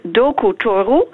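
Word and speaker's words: Doku Toru (ドクター): En dat zegt ook iets over de manier waarop het Japans eh, eh, in elkaar zit. iedere lettergreep in het Japans is altijd een open lettergreep. Dus het is altijd een klinker of een combinatie medeklinker-klinker. Okay, dus dokter wordt doku toru.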